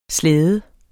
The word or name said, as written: slæde